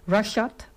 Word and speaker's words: Rvshvd: Rashad, uitgesproken. Ik zei spreek je uit in plaats van R-V-S-H-V-D.